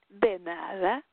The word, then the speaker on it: de nada: De nada. De nada. It's like doom dada. De nada. You have to say it like de nada, like my pleasure.